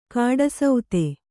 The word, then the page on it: ಕೂಟಸ್ಥ: kūṭastha